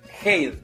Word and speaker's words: Aden: Yo, de hecho, tengo también otro que se llama, le llaman ahí Hade, pero se escribe Aden y ustedes lo han visto ahí en el mercado.